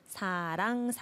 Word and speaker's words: 사랑: This one not wrong or lang. Oh, not okay. like this. 사랑 사랑.